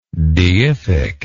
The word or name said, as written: deific